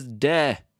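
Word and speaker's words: de (で): D-E. Not D-A-Y, huh? I wonder how many people like see this and they think like day. Let's like compare the pronunciation of those real quick. We have like the English word day, but this is de.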